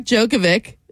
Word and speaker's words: Djokovic: Tennis star Novak Djokovic.